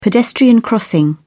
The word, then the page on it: pedestrian crossing: British English